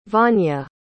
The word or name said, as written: vania